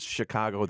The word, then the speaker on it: Chicago: And I want you to pronounce Chicago the right way, not the way. – It's Chicago. – Yeah. – Chicago. – Not the way I say it.